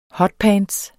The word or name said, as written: hotpants